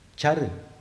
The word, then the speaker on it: car: Char means to move. Char is to move. Funny enough, if you write it in transliteration, Roman, you are going to spell it C-A-R, which also means moving in English.